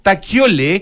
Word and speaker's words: Kjolle: Taquiole, que se escribe K-J-O-L-L-E, en el número 28 del mundo.